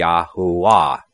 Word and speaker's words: Yahuwah: So the correct pronunciation would not be Jah, it would be Yah, as in Yah, Hallelujah, because we don't say Hallelujah, Yahoo. And the V would have been silent in the 1600s when it was written. And it wouldn't be Va, it would be Wa. So it would be Yahuwah.